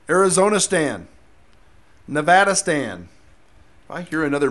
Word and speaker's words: Nevada: It's Nevada. Say it right. I know. It's semantic. No, that's the right way to say it. Ask someone who's from Nevada. They'll tell you.